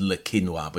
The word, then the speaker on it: Le Quesnoy: And I thought, aha, Le Quesnoy, that's how you say it, Le Quinoa. So that's how I discovered that the pronunciation is Le Quinoa, but it looks like an anglicised version, Le Quesnoy.